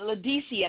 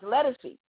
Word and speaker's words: Ledisi: He said, one, I want you to listen to this singer, and we were pronouncing it Ledisi at the time because he had no idea that it was pronounced Letticy.